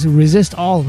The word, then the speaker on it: Resistol: Me encanta que ya es la marca es Resistol, que se escribe así Resistol, pero es Resist All, ¿no?